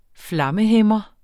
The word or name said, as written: flammehæmmer